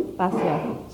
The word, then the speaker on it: Basya: Basya. – Sorry? – Basya. – Spell it for me. – B-A-S-Y-A.